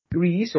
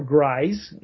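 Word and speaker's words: Gries: And then there's Will Penny from 1968, directed by Tom Grise, or Grise. I know it's pronounced both ways.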